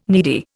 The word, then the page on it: needy: Indian